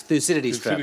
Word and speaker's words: Thucydides: um, it's, it's, uh, in your book, you mentioned several times that uh, that's a Thucydides, Thucydides trap.